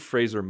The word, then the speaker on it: Fraser: It's a Brendan Fraser movie when Brendan Fraser was still a thing. And yes, I am pronouncing it correctly. That is how you pronounce his last name, is Fraser, not Fraser, Fraser.